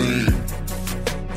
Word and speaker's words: zine: It's pronounced zine.